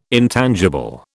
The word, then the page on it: intangible: British English